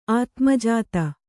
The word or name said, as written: ಆತ್ಮಜಾತೆ